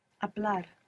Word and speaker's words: Hablar: It begins with a silent H. Hablar. Hablar, good.